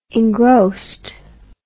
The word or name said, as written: engrossed